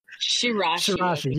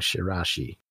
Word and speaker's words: Shiraishi: Sarichi, I don't know how his name is pronounced. – Shirashi. – Yes, it's spelled Shiraishi, but I think they were just saying Shirashi.